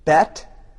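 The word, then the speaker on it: Beit: Bet. You see this all the time in synagogues. They will spell it on the sign, Beth, B-E-T-H. But pronunciation-wise, it's B-E-I-T. It means house.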